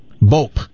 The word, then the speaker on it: Bopp: How do you pronounce the street that is spelled B-O-P-P? – Bope. – There you go. How many people pronounce it Bob? – A lot.